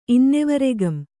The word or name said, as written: ಇನ್ನೆವರೆಗಂ